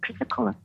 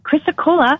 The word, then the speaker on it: chrysocolla: Comes with chrysocolla. Chrysocolla, chrysocolla. Sorry. – Oh, okay. So if I've seen that on a little tag somewhere, I was pronouncing it completely different.